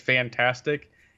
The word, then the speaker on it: Fntastic: Well, I'm guessing they meant to say the company's meant to be pronounced as fantastic. It's F N T A S T I C.